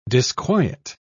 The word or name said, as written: disquiet